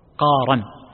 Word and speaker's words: قارا: الاحتمال الأول بالتسهيل للراء قارا كما نطقها القارئ جزاه الله خير، وهذا صحيح فتكون من القار،